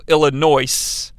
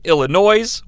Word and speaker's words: Illinois: So the ring announcer says that the one-man gang is from Chicago, Illinois. I fucking hate when people say Illinois. Why do they do it? It's Illinois. The S is silent.